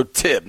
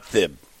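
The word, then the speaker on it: Thibodeaux: Thibodeau's a good player. Help me with the pronunciation of this thing. – Thibodeau. – So Tib, not Thib. – Yeah, Thibodeau. – Okay. – The H is silent.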